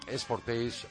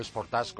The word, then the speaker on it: Sportage: Algo bastante más atractivo esta eh, prueba que hemos realizado, autoprueba que hemos realizado en Cope Auto, al Kia Sportage o Sportage, como quieras eh, pronunciarlo.